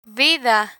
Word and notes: vida: Spanish

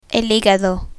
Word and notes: higado: Spanish

